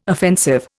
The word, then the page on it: offensive: Indian